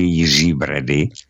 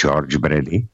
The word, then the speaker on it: George Brady: Protože, vemte si, aféra s panem Jiřím Bradou, on žije v Kanadě, tak mu říkají v podstatě nelogicky Jiří Brady, i když správně, teda když to chtějí vyslovat anglicky, tak by to mělo být George Brady.